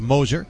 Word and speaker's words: Mosser: Takes a pitch a little outside, ball 1-1-0. Gabe Moser, M-O-S-S-E-R, looks like Mosser, pronounced Moser, 6'4", 185.